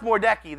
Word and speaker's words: Mordecai: It's pronounced Mordecai. They just mess it up.